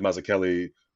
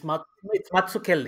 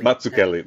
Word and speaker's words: Mazzucchelli: based on that David Mazzucchelli, which I probably butchered that surname, talk he did. – It's Mazzucchelli. – Mazzucchelli, there you go.